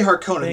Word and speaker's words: Harkonnen: Harkonross? – Harkonnen? I say Harkonnen. They said Harkonnen. They did a short O sound.